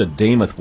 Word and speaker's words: Demuth: You're going to hear a lot of people pronouncing her name Demuth or Demuth. It's Demuth. Minnesota House Speaker Lisa Demuth